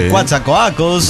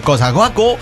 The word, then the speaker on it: Coatzacoalcos: Algunos la pronuncian Cuatza, sí. Cuatzacoacos, sí. Otros redondean más la O como yo, Cuatzacoacos, otros Cosacoaco, y así. Pero bueno, son pronunciaciones.